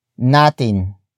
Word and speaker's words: natin: The next word is natin.